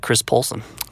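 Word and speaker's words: Kris Poulson: Yeah, you can follow me at Twitter at Chris Poulsen. – All right. And how would you spell that? – K-R-I-S, last name P-O-U-L-S-O-N.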